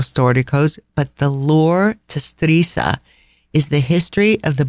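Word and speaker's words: Stresa: And I have been mispronouncing that village name forever. It's Strisa. – Strisa. – I was pronouncing it Stressa.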